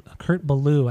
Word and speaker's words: Kurt Ballou: Uh, it was produced by Kurt Ballo Kurt Ballou, I guess. This is how you would pronounce it, but he's the guitarist for Converged, hmm.